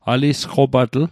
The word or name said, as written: alisqopatl